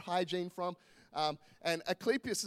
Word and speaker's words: Asclepius: the god Asclepius. Okay, that's exactly how you say it, Asclepius.